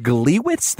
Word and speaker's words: Gliwice: I think it's pronounced Gliwice, the Gliwice incident.